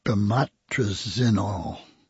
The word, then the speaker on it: Bemotrizinol: It's called Bemotrizinol. It's spelled B-E-M-O-T-R-I-Z. That's Bemotriz-I-N-O-L. Bemotrizinol. Okay. Or B-E-M-T.